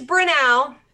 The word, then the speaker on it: Brunel: Brunel, because everybody I talk to pronounces it differently. – Yes, it is Brunel.